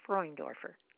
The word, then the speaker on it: Freundorfer: You know, the first syllable rhymes with the word coin, so it's Freundorfer.